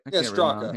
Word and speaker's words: Straka: Strachey. Straka. I can't write. – Straka.